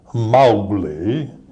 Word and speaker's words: Mowgli: I said, how do you pronounce Mowgli? And this wonderful old voice came out of there, like Edna Mae Oliver, and she said, you pronounce it Mowgli.